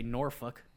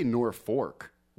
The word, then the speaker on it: Norfolk: They say Norfolk. Where do you get fork out of that? – That's right, the fork, the R instead of... – Yeah, I don't know. – It's a silent L and invisible R.